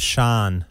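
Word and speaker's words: Shaan: Sean.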